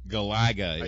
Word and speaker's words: Galaga: Galaga I is the official. Found out is the official pronunciation. – All right. So um, it's the I, the emphasis is on the second syllable.